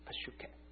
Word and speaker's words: psyche: 那个生命其实希腊文是两个字，爱惜生命的是爱惜一个叫做 Peshuka， Peshuka 这那个字是希腊文的一个字，英文变成 psychology 的那个字根 P-S-Y-C-H-E Peshuka，因为英文不发 P 的音就变成 psychology，希腊文发的时候 P 是发音的，那个是肉体的生命，你爱惜你肉体的生命你就失去你的 Zoe 属灵的生命 Z-O-E。